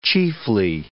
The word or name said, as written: chiefly